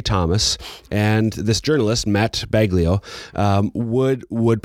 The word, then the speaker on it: Baglio: There was a book that came out a few years ago by a journalist, Matt Baglio, would be the Italian way to pronounce his name, but it's probably Baglio, B-A-G-L-I-O is probably how he says it.